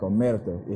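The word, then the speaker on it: omertà: Honestly, the actual word Omerta, they pronounce it correctly like Omerta, if you know how to roll your R's and stuff like that, if I'm, you know what I'm saying, putting it out there correctly.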